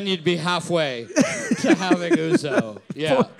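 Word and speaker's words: ouzo: But I know for sure that I am pronouncing Uzo correctly.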